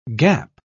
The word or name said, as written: gap